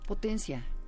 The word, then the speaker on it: Potentiae: Eh, ¿Sí se pronuncia así? – Potencia. Ah, potencia, que decíamos.